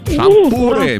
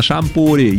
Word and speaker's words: шампур: Итак, запомните, Олечка, и вы особенно, что везде, как бы вы там ни переворачивали, ударение во всех формах существительного падает на букву «у». Шампуры, металлические шампуры, жарить на шампуре, есть с шампура.